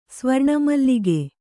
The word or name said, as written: ಸ್ವರ್ಣಮಲ್ಲಿಗೆ